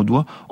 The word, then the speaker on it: doigt: D-O-I-T. Ce G et ce T qui ont disparu de la prononciation du mot doigt,